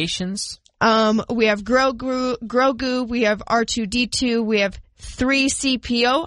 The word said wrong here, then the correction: C-3PO: I know that one. – Nope. C-3PO.